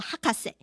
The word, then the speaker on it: 博士: 诶，其实不是哦，这个博士呢这个字哦，这两个字念作这个学位的那个博士 Master 的时候呢是念作 Hakase，所以呢少年侦探团的孩子们平常在叫阿笠博士的时候呢是叫他 Hakase，而不是叫他的名字 Hiroshi 哦。